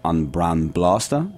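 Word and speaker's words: An Braon Blasta: The name is actually pronounced more like on Bran Blasta. It's an Irish phrase that means the lovely drop, but.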